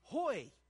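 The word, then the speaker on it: hoy (הוֹי): It's pronounced in Hebrew, "hoy."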